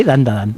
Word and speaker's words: Dandadan: Tenemos Dandadan, creo que lo he dicho bien, Dandadan. – Sí, Dandadan.